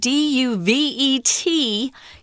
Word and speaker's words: duvet: Duvet is a French word, D U V E T. You don't pronounce the T, it's duvet.